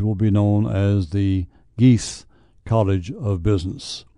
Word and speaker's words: Gies: The gift comes from Chicago businessman Larry Gies. His name is spelled G-I-E-S, Gies, and his wife, Beth.